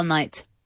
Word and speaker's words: knight: K-N-I-G-H-T. You do not pronounce the K. Like a knee. You do not say knee. No. Knight. No.